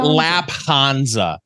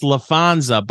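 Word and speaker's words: Laphonza: So he went ahead and appointed Lafonza. – It's spelled with a PH, like fish. – Yeah. – The band, not the animal. So it's. – Are we sure it's not Lapanza? – We're not sure is the thing.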